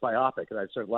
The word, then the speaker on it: biopic: So I used to think it was biopic, but it is actually biopic.